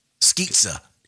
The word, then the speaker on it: Skizza: I think it's pronounced Skizza. – Skizza, like pizza.